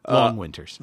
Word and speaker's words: Long Winters: Oh, speaking of John Roderick, he is playing with the Lone Winters at a Gitz show. – Long Winters. – The Lone Winters are long. – Long. – I thought I said long. – Long Winters. – What did he pronounce? I'm pretty sure I said long. L-O-N-G.